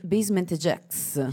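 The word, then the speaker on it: Basement Jaxx: come si legge? Basement Jacks. – Yes.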